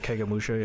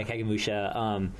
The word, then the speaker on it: Kagemusha: I haven't seen Sanjuro yet, but I've seen, you know, The Seven Samurai and Kagemusha or something. I don't know how to pronounce that. – Yeah, Kagemusha, yeah. – Yeah, Kagemusha.